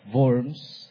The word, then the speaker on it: Worms: so I'm pronouncing it the way it should be pronounced in German, Diet of Worms.